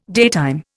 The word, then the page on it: daytime: Indian